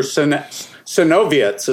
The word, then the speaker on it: Sinowiec: So the chordal thing from the rhythm section is guitar, and on the album it's Andrew Sinowick, but playing the part of Andrew Sinowiec is the Polish pronunciation.